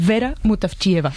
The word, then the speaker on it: Vera Mutavcheva: Zdravka Eftimova y Vera Mutavcheva.